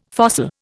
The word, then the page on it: fossil: Indian